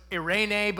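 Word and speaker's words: eirene (εἰρήνη): And so in the New Testament, the word that's used for peace is this Greek word that's pronounced eirene. I suck at rolling my R's. So it's eirene, but you roll the R.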